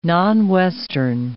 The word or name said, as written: non-Western